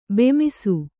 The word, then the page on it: ಬಿಮ್ಮಿಸು: bimmisu